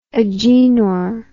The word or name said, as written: agenor